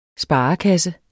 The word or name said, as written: sparekasse